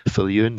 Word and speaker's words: Viljoen: And because it's quite such an unusual name, you won't have any difficulty finding me if you just type in Edward Viljoen. That's spelled V as in Victor, I-L-J-O-E-N, and type Ordinary Goodness, and you'll see it'll take you right to all of my social media and Facebook page.